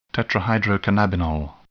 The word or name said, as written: tetrahydrocannabinol